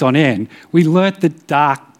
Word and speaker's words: Tak: It's spelt T-A-K, but if you pronounce it correctly, it's pronounced Dak, kind of like dark, but with a T.